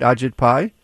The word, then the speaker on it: Ajit Pai: That's how he pronounces his name, right? Ajit Pai? – Yes.